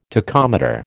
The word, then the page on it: tachometer: American English